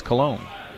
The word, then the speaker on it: Colon: It's spelled C-O-L-O-N, like colon, but it's Cologne.